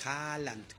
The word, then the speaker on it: Haaland: El otro se escribe H-H-O-L-L-A-N-A-N-D y él es Haaland.